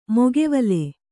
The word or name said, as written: ಮೈಗಾವಲು